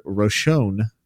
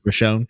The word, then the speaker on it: Roshone: And then the new city lord, Blightlord Roshone, how do you say it? – You got it, man. – Roshone, yeah.